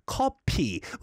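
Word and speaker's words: 커피: And in case anyone's wondering why the answer is coffee, is because if you pronounce coffee in Korean way, it's pronounced as coppi, which is similar to coppi, nosebleed.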